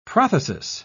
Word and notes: prothesis: British English

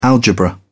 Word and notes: algebra: British English

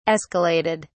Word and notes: escalated: British English

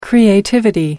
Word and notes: creativity: British English

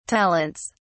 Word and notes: talents: British English

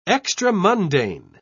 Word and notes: extramundane: British English

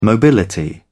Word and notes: mobility: British English

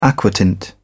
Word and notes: aquatint: British English

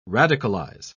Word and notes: radicalise: British English

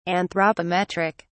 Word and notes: anthropometric: British English

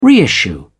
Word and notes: reissue: British English

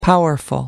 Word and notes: powerful: American English

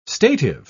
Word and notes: stative: British English

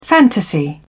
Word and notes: fantasy: British English

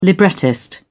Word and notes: librettist: British English

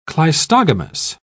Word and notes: cleistogamous: British English

